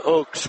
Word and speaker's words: Ochs: 5'5", Sarah Oaks, spelled O-C-H-S, pronounced just like the tree.